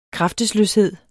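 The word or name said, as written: KRAFTESLØSHED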